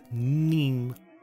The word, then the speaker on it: Nîmes: Nîmes. – Yes, Nîmes. – Nîmes. – I just remember it rhymes with meme, and then I can do exactly.